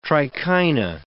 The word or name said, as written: trichina